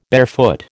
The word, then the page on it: barefoot: American English